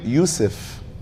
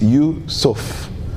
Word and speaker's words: Yusuf: Prophet Yusuf. Yusuf alayhi salam. I know Arabs, especially from the Middle East, they call him Yusuf. No, it's Yusuf. Yusuf.